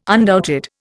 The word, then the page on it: undoubted: Indian